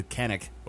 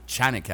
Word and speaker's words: Canik: Uh, the Canik or Chanik actually is the correct pronunciation.